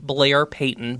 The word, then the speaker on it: Blair Payton: If you go to my Instagram, at Blair Payton, B-L-A-I-R, because Starbucks doesn't know how to spell it, P-E-Y-T-O-N.